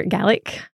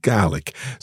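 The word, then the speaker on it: Gàidhlig: So there's a little bit of a pause in the first syllable there. – There is. There's an accent on the A in Gaelic. So we want to kind of draw out that vowel a little bit.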